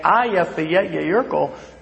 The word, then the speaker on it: Eyjafjallajökull: And you could hear sometimes the slowing down of the pyroclastic, or Natalie, especially as she's saying, Eyjafjallajökull, but it was it was pronounced correctly and it was enunciated.